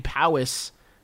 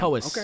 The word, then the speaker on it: Powys: John is spelled like J-O-H-N. Cowper is spelled C-O-W-P-E-R, C-O-W-P-E-R. Okay. And his last name, Powis, is spelled P-O-W-Y-S. – Damn. – Powis.